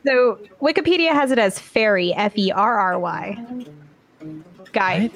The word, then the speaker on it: Fieri: Phonetically, they have F E. It's ferry.